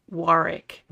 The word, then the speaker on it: Warwick: Just because there is a W in his name, we have been pronouncing his name wrong. It is, he pronounces it Warwick. – Warwick? – Warwick, not Warwick, but Warwick Davis.